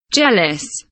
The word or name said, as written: jealous